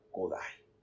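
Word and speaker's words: Kodály: Yo estudié en Keshkemit y allí me gradué en un sistema de pedagogía moderna eh, creado por Zoltan Kodai. Se escribe Kodali, pero se pronuncia Kodai.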